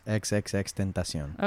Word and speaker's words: XXXTentacion: So I still don't know how to say his name correctly. – Bars. XXXTentacion. – Okay. Is it actually said that way? – Yes.